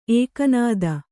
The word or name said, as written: ಎಕ್ಕನಾದಿ